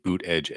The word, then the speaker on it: Buttigieg: And so that's what they came up with was the boot edge thing.